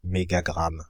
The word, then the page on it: mégagramme: Paris; [me.ɡa.ɡʁam]; France (Île-de-France); /me.ɡa.ɡʁam/